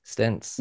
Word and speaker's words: Stentz: I wasn't sure if it was like Polish or something and the TZ might have a ch sound to it or... – It's German. – Yeah. – So if you pronounce it the German way, it would technically be Stentz, but you know, no one does that in the US.